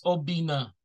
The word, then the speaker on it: Obinna: Um, so my name is Obinna Nwagboso and um, it's spelled O B I N N A, short form Obi.